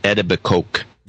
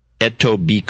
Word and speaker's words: Etobicoke: He's in Ontario, but the town is Etobicoke. – What's his name? I stepped on you. – Etobicoke.